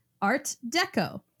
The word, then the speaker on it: Art d'Ecco: So, this week we've got an artist called Art d'Ecco. Uh, pronounced just like that, except the echo is like the Italian version of echo, where it's like echo, like here, but it's pronounced deco.